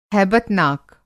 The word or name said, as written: हैबतनाक